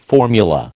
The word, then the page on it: formula: American English